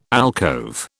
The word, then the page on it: alcove: British English